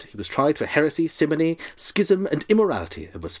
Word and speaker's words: simony: Hang on, what's himony? Himony, himony, himony who? – Heresy. Did I say himony? – I thought you said himony. – No, I shouldn't have. Simony. I said simony, which is lent.